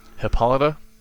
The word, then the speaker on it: Hippolyta: Is that the one that I call Hippothalia? – Yes. – Oh. God, I wish. How do you say it? – Hippolyta. – Oh my god, I was way off.